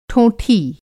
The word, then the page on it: ठोंठी: Hindi